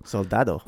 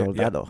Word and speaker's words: Soldado: Soldado. – Soldado? Is that it? Okay. – Soldado. Yeah, there's a D in there.